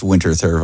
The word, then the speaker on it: Winterthur: I really like Winterthur viburnum, and some people would call it Winterthur and pronounce it differently, but it's Winterthur viburnum for all common English-speaking people here. – And it's hard to pass that TH and just leave it with a T.